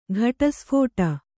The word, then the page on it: ಘಟಸ್ಫೋಟ: ghaṭa sphōṭa